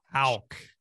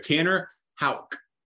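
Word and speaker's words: Houck: How do you say his last name? John Wack. – Tanner Hulk. – Hauk. – This is a good test right here. Tanner Hauk. – Yeah, I just said it.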